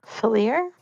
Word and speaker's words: Fenrir: I think it's Fenrir, right? – Fenrir? You did Fenrir. Write it somewhere. It's in role play. – Oh, thank you. And so Fenrir starts in towards that pedestal in this room.